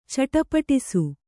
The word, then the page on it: ಚಟಪಟಿಸು: caṭapaṭisu; kannada